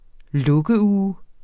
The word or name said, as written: lukkeuge